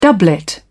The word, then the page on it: doublet: British English